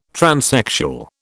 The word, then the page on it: transsexual: British English